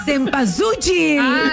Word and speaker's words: Cempasúchil: Selpazuchitl. – ¿Cómo? – Selpazuchitl. – ¿Cómo se dice, flaca? – Si te la chuan. – Selpazuchitl. – Por ahí vas, pero no se dice así, flaca. – Cempasúchil.